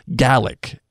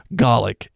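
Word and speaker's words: Gaelic: Uh, one, the Scottish language is pronounced Gallic, as in me in my gal, and not Gallic.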